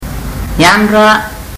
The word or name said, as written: yadra